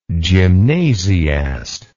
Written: gymnasiast

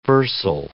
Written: versal

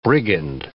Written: brigand

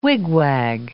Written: wigwag

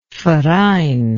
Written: verein

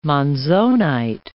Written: monzonite

